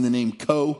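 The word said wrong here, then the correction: Koch: because it's K-O-C-H, and I know a family pronounced that way. He's like, nope, it's Cook.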